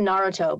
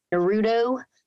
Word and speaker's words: Naruto: Like it's Naruto, but lots of people say Naruto. It's not Naruto. It's Naruto because this emphasis is on the first syllable instead of the middle one, which is what we're used to.